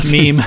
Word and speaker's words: meme: I'm going to make a mem, memmy, mem. What are Meme, meme. I'm going to make a meme. You're laughing at me because I don't even know how to pronounce it.